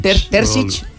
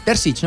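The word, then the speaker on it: Terzić: com es pronuncia? – Terzic. – Terzic, no? – Sí, però... – Me pareix, correcte.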